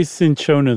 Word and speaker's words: cinchona: I always say cinchona too, but I'm looking right at it, and I don't see that first H. – Unless it's a weird pronunciation thing, I think I've probably just been saying it wrong.